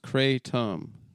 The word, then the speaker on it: Kratom: Speciosa, pronounced Speciosa, and Kratom, pronounced Kratom.